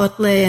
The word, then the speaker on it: Otlet: Um, and these are two men, Paul Otlet and um, if I'm, am I pronouncing that right? Yes, yes, okay.